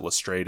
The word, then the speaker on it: Lestrade: I've heard it pronounced Lestrade. I think since they pronounce it Lestrade in the show. – Inspector Lestrade. – That's just the pronunciation we go with.